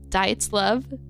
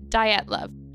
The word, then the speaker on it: Dyatlov: It's not Dyatlov, but it's like Dyatlov.